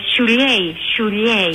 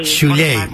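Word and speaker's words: Šiauliai: Είναι λίγο δύσκολη η προφορά. Σιαούλια, Σιουλιέι. – Σιουλιέι, μάλιστα. Ναι.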